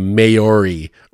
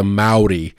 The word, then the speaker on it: Maori: That's, yeah, because I've been pronouncing this wrong. This is like a bad thing. This is like worse than calling Kiev Kiev. I've been calling them the Maori or the, and it's the Maori.